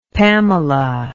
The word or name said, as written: pamela